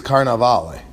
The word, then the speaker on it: Cannavale: So how do I pronounce it? Cannavale? – Yeah, I think it's Cannavale.